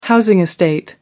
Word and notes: housing estate: American English

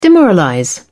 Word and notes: demoralize: British English

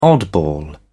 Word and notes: oddball: British English

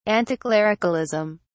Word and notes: anticlericalism: British English

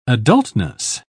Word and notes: adultness: British English

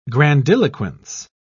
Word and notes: grandiloquence: British English

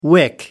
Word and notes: wick: American English

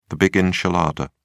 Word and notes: big enchilada: British English

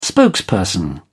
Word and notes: spokesperson: British English

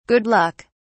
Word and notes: good luck: British English

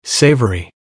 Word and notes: savory: American English

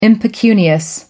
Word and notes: impecunious: British English

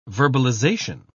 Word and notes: verbalization: British English

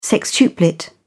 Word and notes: sextuplet: British English

